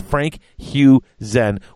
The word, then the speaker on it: Frank Husen: It's Frank Huzen with an emphasis on the putting the stress on the Hugh, Frank Huzen.